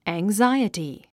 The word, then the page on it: anxiety: æŋzáiəti; アングザァイエティ